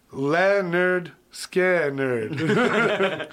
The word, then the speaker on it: Lynyrd Skynyrd: It's off the 1973 album, pronounced Lynyrd Skynyrd, which you literally can't say it without sounding like an idiot.